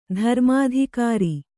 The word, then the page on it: ಧರ್ಮಾಧಿಕಾರಿ: dharmādhikāri